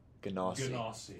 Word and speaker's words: Genasi: Genasi. – Genasi. – Actually, they prefer the soft G. – Genasi.